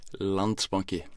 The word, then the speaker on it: Landsbanki: Landsbanki.